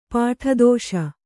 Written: ಪುಟದೋಷ

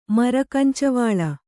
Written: ಮರಕಂಚವಾಳ

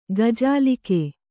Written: ಗಜಾಲಿಕೆ

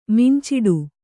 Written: ಮುಂಚುಟು

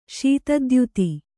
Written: ಶೀತದ್ಯುತಿ

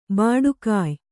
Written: ಭಟ್ಟಗೆಯಿ

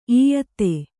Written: ಇಯತ್ತೆ

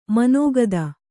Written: ಮನೋಗತ

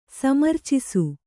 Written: ಸಮರ್ಚಿಸು

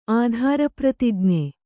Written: ಆಧಾರಪ್ರತಿಜ್ಞೆ

